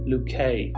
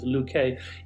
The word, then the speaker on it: Luquet: Today I'm going to be talking about a guy by the name of Matthew Luquet. His last name is spelled L-U-Q-U-E. For a long time I thought it was Matthew Luque, but I think the proper pronunciation is Luquet.